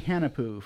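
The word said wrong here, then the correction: Hunahpu: from Cigar City? And I'm probably saying it wrong. – Hunapu. – Hunapu.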